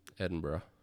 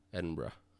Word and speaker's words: Edinburgh: So he is born in Scotland in 1795, and he trained to be a brewer in Edinburgh? – Edinburgh. – Edinburgh? – Edinburgh. – Edinburgh. – Yep. – Thanks. I knew you'd know how to say it, and I didn't.